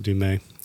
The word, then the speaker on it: Du Mez: She's a lovely uh, Kristen Demuse, Kristen Demuse from uh, maybe. sorry, is the way I think you pronounce it Du Mez.